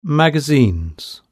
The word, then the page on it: magazines: British English